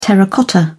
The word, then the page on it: terracotta: British English